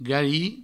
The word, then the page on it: garir: gari